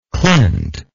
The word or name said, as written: clint